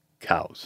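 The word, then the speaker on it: Coues: But he wrote the Checklist of Birds in North America, and he has a footnote where he explains how to pronounce his name. – No. – He does. It says C, and he says C-O-W-Z, cows.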